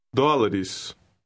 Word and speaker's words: dólares: The unstressed ES ending is pronounced is. – Try word 14. – Dólares.